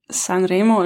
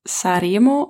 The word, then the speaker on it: Sanremo: Also natürlich heißt das Sanremo und nicht Saremo oder so. Da ist schon ein N, aber dieses N ist nur angedeutet.